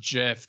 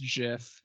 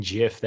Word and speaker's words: GIF: It's pronounced gif. The creator said it's pronounced gif.